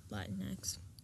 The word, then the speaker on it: Latinx: Just Latinx. – But you would just, like, say the X at the end.